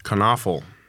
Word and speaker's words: Knievel: Knievel. That's very important that you pronounce it.